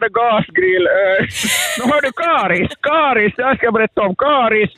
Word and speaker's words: Karis: Karis, som det heter. – Karis. Karis säger jag det på mitt uttal.